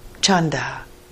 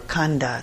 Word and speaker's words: chanda: The next wholesome factor of mind is wholesome desire, chanda, or khanda, it's sometimes pronounced in Pali.